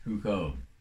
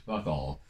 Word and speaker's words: Foucault: Foucault. I used to pronounce it Fuckall.